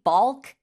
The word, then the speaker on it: balk: It's spelled B-A-L-K, bulk at something.